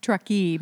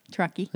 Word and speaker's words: Truckee: It's pronounced Truckee. – It's Truckee. – Truckee.